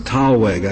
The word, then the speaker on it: thalweg: Mr. Kaiser, I think that word is pronounced thalweg. I know it has been pronounced thalweg by everybody who's spoken. I've checked it in a couple dictionaries. It's based on the old German word thal, which is the word for valley, and that's pronounced tal, I think, not thal.